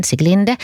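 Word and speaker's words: Sieglinde: Och så har vi då Sikli, eller Siglinde, hur den uttalas, Siglinde.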